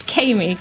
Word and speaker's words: Kamie: Yes, Kamie Dickherbert. – See, I mispronounced both of them. That's Kamie. That's like Amy with a K.